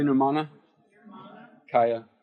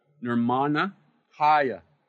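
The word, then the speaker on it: Nirmanakaya: Say Nirmana Kaya. So Nirmana, see, good. That's where it's accented there because of the long syllable there. Nirmana Kaya, see, like that.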